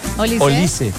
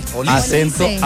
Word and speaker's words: Olise: Olise. – Con tilde en la I. – Con la I. – Olise.